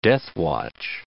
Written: deathwatch

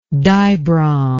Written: dibrom

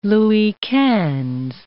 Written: Louis Quinze

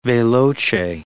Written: veloce

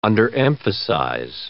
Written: underemphasize

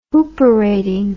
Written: hooperating

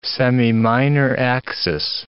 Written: semiminor axis

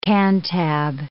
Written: Cantab

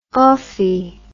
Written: offie

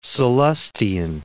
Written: Sallustian

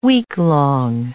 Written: weeklong

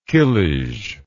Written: kilij

